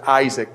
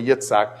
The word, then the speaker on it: Yitzhak: Yitzhak means laughter, and you guys pronounce it Isaac, but it's really Yitzhak.